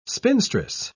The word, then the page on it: spinstress: British English